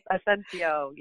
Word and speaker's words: Asencio: Yes, Asencio.